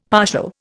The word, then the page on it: partial: Indian